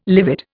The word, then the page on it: livid: Indian